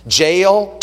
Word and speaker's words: Jael: And the Bible says that he went in the tent to hide, and Jael, the wife of Heber, took a nail. It rhymes. Jael took a nail.